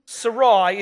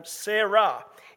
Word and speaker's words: Sarai: Sarai is the Eastern pronunciation of her name, Eastern Semitic pronunciation of her name. Sarah is the Western Semitic pronunciation of her name.